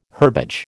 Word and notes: herbage: American English

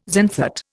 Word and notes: xanthate: Indian

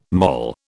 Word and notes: moll: British English

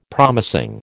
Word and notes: promising: American English

